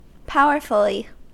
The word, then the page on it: powerfully: American English